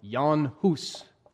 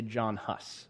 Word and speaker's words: Jan Hus: Technically, it should be pronounced more like Jan Hus, but I'm just going to say John Huss.